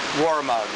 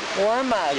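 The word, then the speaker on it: Waramaug: Warramug. – Warramug.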